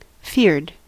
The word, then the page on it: feared: American English